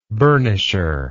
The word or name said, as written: burnisher